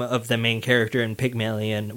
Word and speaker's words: Pygmalion: Pygmalion? – Yeah, Pygmalion.